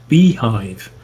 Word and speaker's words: bhyve: B-H-Y-V-E, which is pronounced Beehive, the BSD hypervisor, which is quite neat, actually.